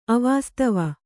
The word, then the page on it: ಅವಾಸ್ತವ: avāstava